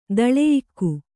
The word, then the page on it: ದಳೆಯಿಕ್ಕು: daḷeyikku